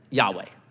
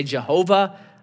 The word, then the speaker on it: Yahweh: So whenever I see capital L, capital O, capital R, capital D, I just change it to the best way we know how to pronounce it, Yahweh. Some people say Jehovah. I think Yahweh is correct. So, freebie. O Yahweh, if it's not capital L-O-R-D, if it's capital L, little O-R-D, it's most likely Adonai.